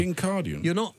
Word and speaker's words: Kincardine: It's Andrew Bowie, Conservative MP for West Aberdeenshire and Kincardine. Is that how you say it, Andrew? – Right in one, James. – Right in one. – Kincardine.